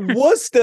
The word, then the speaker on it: Worcester: Yeah, I mean, you're from Worcester. It's spelled Worcester. – Yo, Worcester.